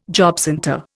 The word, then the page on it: jobcentre: Indian